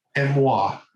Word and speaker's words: Emoi: Emois. It's spelled E-M-O-I.